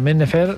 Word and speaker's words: Men Nefer: Per què pronuncies al revés l'accent? Men Nefer? – Men Nefer, perquè és egipci.